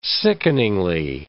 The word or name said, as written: sickeningly